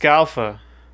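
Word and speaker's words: Scialfa: Yeah, I think it's just Scalfa. Scalfa, she. – Because I ju- I looked it up, and uh, that was the pronunciation. I think the I is just a just to throw you off.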